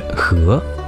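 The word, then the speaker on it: he (荷): In Chinese, the lotus has two different names, respectively pronounced as lian and he.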